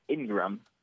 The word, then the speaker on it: Ingram: Ingram, which was actually also mispronounced. – Ingraham? – Oh, really? – It's pronounced Ingram. I don't know why they would mispronounce that, but I-N-G-R-A-M.